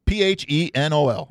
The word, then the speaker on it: phenol: Phenol? – Yeah. Phenol. Am I saying that right? – Right. Yeah.